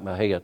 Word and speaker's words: Mexia: Like Mahia, Texas, they have the sign that says a great place to live regardless of how you pronounce it. And Mexia. And so anyhow, there is Baal, and he marries Jezebel, the daughter of Ethbaal.